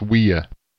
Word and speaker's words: Ouya: And, oh, I watched some demos from their website, and it's pronounced Ouya. – Ouya? – So it's like, yeah, it's like French, kind of.